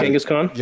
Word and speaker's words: Kangaskhan: The oh, uh, Kangaskhan.